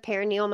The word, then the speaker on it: perineal: Would you be comfortable telling kind of what role you play in that for men who've never heard of perineal massage? – Yeah. Oh, I might be saying that wrong. Perineal. Thank you. – Yeah, I think you can say it in multiple ways.